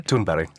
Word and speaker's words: Thunberg: I'm a great fan, and I take it you were giving us the correct Scandinavian pronunciation of her surname, is that right? – Thunberg, yeah.